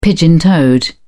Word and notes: pigeon-toed: British English

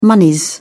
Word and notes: monies: British English

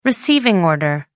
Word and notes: receiving order: American English

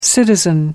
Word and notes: citizen: American English

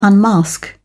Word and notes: unmask: British English

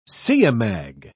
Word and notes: siamang: British English

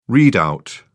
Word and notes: readout: British English

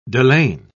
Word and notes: delaine: British English

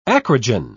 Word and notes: acrogen: British English

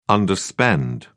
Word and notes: underspend: British English

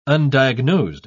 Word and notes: undiagnosed: British English

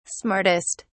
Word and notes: smartest: British English

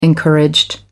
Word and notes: encouraged: American English

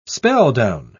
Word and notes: spelldown: British English

